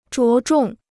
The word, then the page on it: 着重: zhuó zhòng